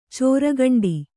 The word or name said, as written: ಚೋರ್ಗಂಡಿ